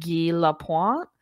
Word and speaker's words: Guy Lapointe: Allie and Teddy meet with the guy whose information that they got, and his name is spelled Guy, but he pronounces it Guy Lapointe. – That's the French way to pronounce it.